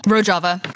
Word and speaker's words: Rojava: Rojava, hard J.